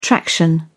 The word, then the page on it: traction: British English